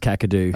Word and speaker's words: Kakadu: Whether you're heading to Litchfield, Kakashnu, Catherine, or anywhere off the beaten track. – It's pronounced Kakadu.